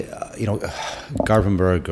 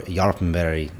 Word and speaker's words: Garpenberg: It was interesting to see, Garpenberg or Jarpenberg, as they might pronounce it, which I was corrected today by a Swedish guy.